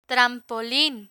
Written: trampolin

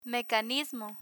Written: mecanismo

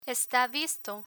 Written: esta visto

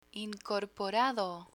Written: incorporado

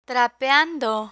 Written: trapeando

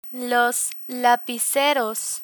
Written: lapiceros

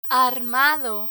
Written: armado

